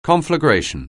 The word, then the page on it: conflagration: anglais